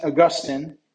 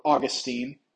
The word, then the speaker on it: Augustine: Saint Augustine, or Augustine, you've probably heard it pronounced both ways, was probably one of the most influential and easily one of the most important Christian philosophers and theologians from antiquity.